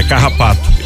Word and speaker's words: carrapato: E vamos pra lida, porque se o gado morrer, o garrapato passa fome. Não é garrapato, é carrapato.